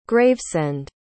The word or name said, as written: gravesend